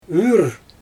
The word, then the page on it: yrr: Xyzyl